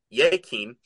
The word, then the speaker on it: Jakin: Jakin, pronounced, uh, sorry, Yakin, uh, means he will establish, and Boaz signifies in him is strength.